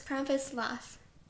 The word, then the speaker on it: Krampuslauf: It's Krampuslauf.